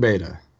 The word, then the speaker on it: Beta: You can call me Spensr Beta. – Spensr Beta. I always get confused between Beta and Beta. I at this point forget which one is the American and which one is the English pronunciation.